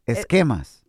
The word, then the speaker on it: esquemas: I think your album va a quemar. – Esquemas. – Esquemas. – Yeah, like schemes. – Okay. Oh, yeah. Okay. I'm all esquemas. Wow. – There's no accent on the A. Esquemas.